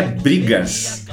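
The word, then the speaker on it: Breegas: E esse EP, o nome desse EP é Brigas. É Brigas com uma brincadeira com bregas que tem a ver com a banda, o estilo. Porque se escreve B-R-E-E-G-A-S, é.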